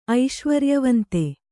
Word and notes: ಐಶ್ವರ್ಯವಂತ: Kannada

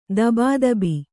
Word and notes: ಧಬಧಬೆ: dhaba dhabe

